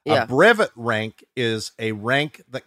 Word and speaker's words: brevet: Oh, brevet. Okay. – And that's a weird word. And what it is in the Sea organization. – Did I say it wrong? – No.